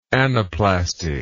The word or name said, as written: anaplasty